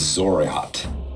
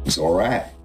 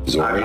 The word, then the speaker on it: Xoriat: "That is Zoriat. X-O-R-I-A-T. Would you say that? Zorat. – Zoriat. I've always sort of pronounced it as Zoriat. Yeah. – Zoriat, yes. – You got it right.